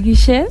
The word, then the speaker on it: Guixe: Entonces se pronuncia Judith Eril Guichet, lo cual es bastante complicado tanto aquí como en para España, todos, para, todo el mundo.